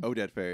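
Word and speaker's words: Oded Fehr: And I think, Omed Fair, is that how you say his name? – Oded Fair.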